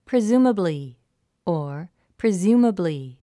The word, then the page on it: presumably: prizúːməbli; プリズーマブリィ